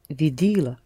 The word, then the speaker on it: видела: Я видела. – The first syllable accented.